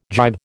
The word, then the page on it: gybe: American English